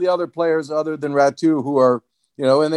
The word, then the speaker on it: Ratu: Perhaps you can tell the listeners how to pronounce Ratu. Did I get that part right? – You did. You did indeed.